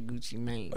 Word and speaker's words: Gucci Mane: How he probably says it, but I probably say Gucci Mane. – Well, yeah, I mean, whatever. – I'm just saying it the way it's pronounced, you know, with the long A.